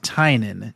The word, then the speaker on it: Tynion: I did find out, I was just doing a little bit of research, it is pronounced Tynan. James Tynan the 4th. – So you pronounced it wrong, like, 3 times. – That's how I've been pronouncing it in my head, because that's how it's spelled, is Tynion. Yeah. But it's Tynan.